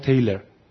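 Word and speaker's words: Taylor: اما آمریکایی نمیگه ریچارد تیلور، میگه ریچارد تیلر.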